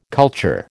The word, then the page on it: culture: American English